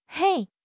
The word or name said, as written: hei1